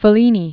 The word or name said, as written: Fellini